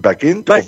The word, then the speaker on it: Bagent: El suplente es Tyson, ¿cómo se dice? Bagent, sí, que entró en protocolo de conmoción cerebral, ¿te imaginas? – Así es, así es.